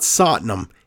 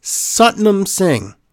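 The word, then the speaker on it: Satnam: It's not platinum satinum. It's not sotinum. It's actually pronounced Sutnam Singh.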